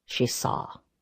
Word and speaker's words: Sinsar: S-I-N-S-A-R, he spelled. Sinsar? I said it phonetically. He shook his head. – She saw. She saw do.